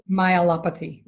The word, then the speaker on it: myelopathy: And I got really fascinated with the story that you told about your dog who had degenerative myopathy. I'm saying that right. Am I not? – Degenerative myelopathy. – Myelopathy. Mm-hmm.